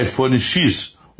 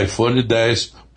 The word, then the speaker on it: iPhone X: Muita gente irá pronunciar iPhone X, o que não tem nenhuma importância, mas segundo a Apple, o correto é iPhone 10, pois o X representa em algarismo romano os 10 anos de lançamento do primeiro iPhone da Apple, ocorrido em 2007.